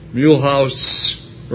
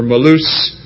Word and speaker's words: Mulhouse: and also the brethren in Mulhouse, or Malouse, France, depending on how you want to pronounce it.